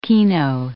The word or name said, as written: keno